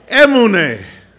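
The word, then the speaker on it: emunah: It's not orale. It rhymes with orale. Emune. Emune. Our emune is actually, I think, the proper pronunciation.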